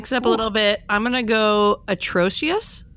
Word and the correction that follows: Atrocitus: I can't even say it properly, which does not bode well for me. Atrocitus.